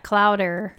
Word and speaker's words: clowder: Clowder? – Like chowder, but clowder.